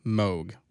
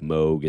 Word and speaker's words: Moog: And the reason I say it that way is that's the general pronunciation for like North American dialects. Like Moog is just what it would be, because the guy, it's a guy's last name, Robert Moog. He calls himself that.